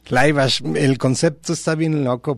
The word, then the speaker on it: Laibach: Alle Gegen Alle se llama esta marcha semimilitar de Laibach o Laibach. – Laibach. – Como lo quieran pronunciar en alemán o en esloveno.